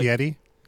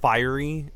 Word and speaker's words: Fieri: It's pronounced fiery, as in his hair.